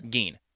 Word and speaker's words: Gein: Gein? I never know how to pronounce that name. – Gein.